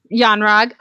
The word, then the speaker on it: Yon-Rogg: The Yon Rogue. Is that his? Or Yon Rogue? – I think. – Yon-Rogg. Okay, thank you.